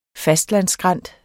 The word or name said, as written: fastlandsskrænt